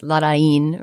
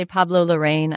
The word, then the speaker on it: Larraín: And if you did a little bit of research into what the accent means, you could figure out that it's Larraín, right, with that accent on the end.